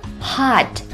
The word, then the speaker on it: Hot: Hot 的字母 T 在这里一般会停一拍不发音， Hot Hot.